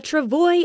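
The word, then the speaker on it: travois: What is a travoyus? – It is pronounced travoy.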